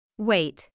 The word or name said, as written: wait